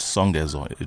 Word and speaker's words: Songezo: My name is pronounced Songezo. It is not with an I at the end.